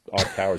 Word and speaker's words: iCaur: iCar, which is spelled I-C-A-U-R, but that's iCar is how they were pronouncing it.